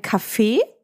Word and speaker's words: Kaffee: Ich würde sagen, wir trinken einen Kaffee, betont auf der zweiten Silbe.